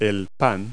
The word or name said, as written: bread